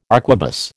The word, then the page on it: arquebus: American English